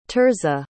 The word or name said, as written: Tirza